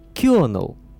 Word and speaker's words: Colonel: Первый шок, изучающие английский язык, испытывают тогда, когда видят транскрипцию этого слова – Colonel – Куда, блин, делось Оло? Англичане напрочь жевали, что ли?